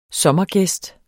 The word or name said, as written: sommergæst